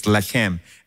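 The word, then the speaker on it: lechem (לֶחֶם): And by the way, the word bread in Hebrew is lechem. And the word fight is lacham. Literally, from the same words, it's literally spelled the same way. Lechem, lacham. And it's just pronounced differently.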